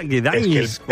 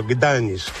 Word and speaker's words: Gdańsk: ¿Te acuerdas, no, cómo te explicaba Eva la pronunciación correcta? Gdańsk, ¿no? – El polaco es enrevesado donde lo haya, sí. Al final aprendí a decirlo, Gdańsk. – Gdańsk.